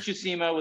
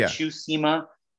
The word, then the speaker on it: Tsushima: Ghost of Tsushima was fucking amazing. Am I saying that right? – Yeah. – Tsushima.